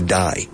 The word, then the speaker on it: dye: To dye. Teñir el pelo. To dye. D-Y-E. Dye. Se pronuncia igual que morir. Pero con Y.